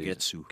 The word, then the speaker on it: Kagetsu: We got Kagetsu, if I pronounce it. Kagetsu.